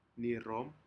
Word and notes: nirom: Aneityum

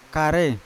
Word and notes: kare: Yawa

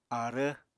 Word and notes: aarv: Apatani

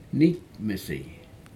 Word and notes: nikmesi: Aneityum

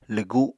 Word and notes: lwgu: apatani

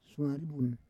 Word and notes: suari vun: Roon

